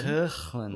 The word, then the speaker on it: Hoechlin: Hoechlin. – Which I'm assuming is how you pronounce it. – It is. You've got it 100% right.